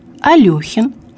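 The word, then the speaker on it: Алёхин: В именах собственных. Алехин, Аксенов, Шмелев.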